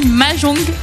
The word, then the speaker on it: mahjong: Et si je vous dis Mahjong, excusez-moi pour ma prononciation. – C'est très bien.